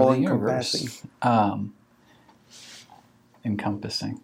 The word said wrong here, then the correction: encompassing: Encompassing, thank you. – Okay. – Wrong inflection onto the wrong syllable.